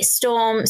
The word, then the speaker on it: Storme: The nuggets would be Storm.